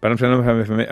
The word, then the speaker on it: Varlam Shalamov: Són relats de Colima de Varian Shalamov. – Varlam Shalamov. – Oh, molt bé, que tu el pronuncies molt bé, que tu la deus admirar.